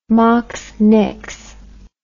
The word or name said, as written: moxnix